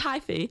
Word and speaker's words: hyphae: So with regard to how they actually move from tree to tree via the mycorrhizal network, the mycelium have, I believe it's pronounced hyphae? Yes. I literally did an entire degree in this and pronunciation still trips me up really bad. So it's hyphae, that the nutrients move along through.